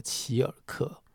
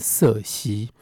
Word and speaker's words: Circe: 呃，女主人恍然大悟，女主人叫做齐尔克，在某些翻译的版本里面，用英文的发音就是说他把它翻译成瑟西（C I R C E）。那我们根据希腊文的念法，就是齐尔克。